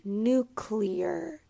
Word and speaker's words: nuclear: We want to make sure that we avoid saying nuclear, like clear, as in something that is able to be seen through. But we want to say nuclear. Nuclear. It actually has three syllables instead of one.